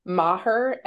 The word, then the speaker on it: Maher: My Twitter handle is OK Maher, M-A-H-E-R. Unlike Bill Maher, I say it correctly.